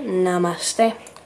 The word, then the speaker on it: namaste: Y tenemos indi, que nuestro hola se pronuncia namaste.